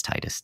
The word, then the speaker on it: Tidus: I know it's Tidus.